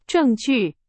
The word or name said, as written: 证据